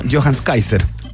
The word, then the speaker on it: Johannes Kaiser: Tiene que ver con los dichos absolutamente descalibrados, deleznables y destemplados del diputado electo eh, por el Partido Republicano, Johannes Kaiser.